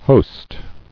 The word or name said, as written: host